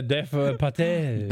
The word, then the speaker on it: Dev Patel: Äh, ist es Dev Patel? Ich glaube, es ist Dev, Dev Patel.